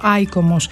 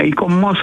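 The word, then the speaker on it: ICOMOS: Αν δεν το γνωρίζετε, αυτό ακριβώς κάνει το ΙΚΟΜΟΣ ή το, δεν ξέρω αν πρέπει να το πω, ΑΙΚΟΜΟΣ. Πρώτα απ' όλα, καλησπέρα σας. – Καλησπέρα σας. Ναι, ΙΚΟΜΟΣ θα το λέγαμε, γιατί έτσι προφέρω όλο με τον γαλλικό τρόπο. – Με τον λατινικό, είπατε.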